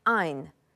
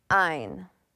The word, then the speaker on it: Ayn: It is pronounced Ayn, as if with long I. Ayn.